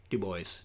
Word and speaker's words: Dubois: Dubois, depending on, that's how they pronounce it in the audiobook.